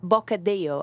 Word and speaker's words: bocadillo: So the word for sandwich, bocadillo.